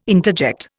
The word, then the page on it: interject: Indian